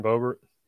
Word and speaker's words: Boebert: Did I say her name right? – Yeah, Lauren Boebert.